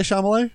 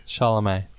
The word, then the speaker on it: Chalamet: Did I say Chalamet? – Chalamet.